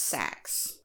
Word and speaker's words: Sach: Now that's spelled S-A-C-H. So I assume it's pronounced sax.